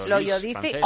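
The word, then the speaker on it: Loiodice: Loyodice.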